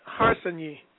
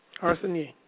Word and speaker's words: Harsanyi: Dave Harsanyi. – Okay. Harsanyi.